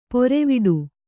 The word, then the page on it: ಪೊರೆವಿಡು: poreviḍu